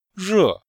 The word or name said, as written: re4